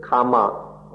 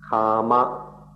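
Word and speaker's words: kāma: The words, don't confuse it with kama or karma, but kama, K long A M A, or sexual existence.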